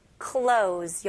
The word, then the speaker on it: clothes: Cluttered with clothes. 여기서는 clothes는 Z 발음으로 해주시면 돼요. 오케이, 바닥에는 옷이 어지럽게 널려 있어.